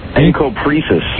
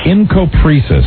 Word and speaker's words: Encopresis: What is the correct pronunciation of the first one again? – Encopresis. – Encopresis.